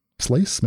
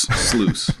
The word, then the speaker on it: Sluice: Memory Sluice. I don't know if I'm saying that right. Slice? Memory Slice? Sluice? – I think it's just Sluice. Sluice.